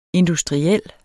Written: INDUSTRIELT

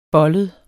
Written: bollet

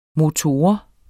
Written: MOTORERNES

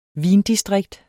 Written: vindistrikt

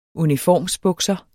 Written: UNIFORMSBUKSER

